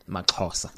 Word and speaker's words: MaXhosa: So talk to us about the story about how it is that uh, MaXhosa. Did I say it right? – MaXhosa. MaXhosa. Yeah. Sounds better.